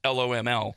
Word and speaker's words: loml: So I'm almost certain it's supposed to be pronounced L-O-M-L because it means love of my life, but love is replaced by loss.